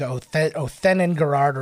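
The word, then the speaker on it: Othenin-Girard: Okay, so I mispronounced her name earlier. It's Dominique Othenin-Garrard or Othenin-Garrard.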